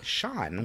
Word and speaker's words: Sian: Which, when I first looked at the name, it's spelled S-I-A-N, and I was like, it's Cyan, a lady. – Cyan, Sean. Yeah, and then I went and looked it up and found out that it was a woman. – Really? It's pronounced Sean? – Sean. – That's really interesting. S-I-A-N.